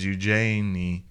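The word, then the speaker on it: Eugénie: Eugene von Tunzelman. – Eugenie. Eugenie von Tunzelman. – But it's got a little accent on the E, so it's Eugenie.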